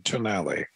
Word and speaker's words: Tonale: Is it the Tonale or the Tonale? – They want it to be the Italian pronunciation, Tonale.